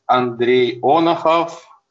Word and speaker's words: Andrey Onokhov: My name is Andrey Onokhov.